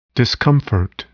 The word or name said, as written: discomfort